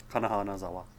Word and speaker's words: Kana Hanazawa: I don't know how to say her name exactly, but it's Kana Hanazawa? – Yeah, that's right. Yes. – Okay. – Kana Hanazawa.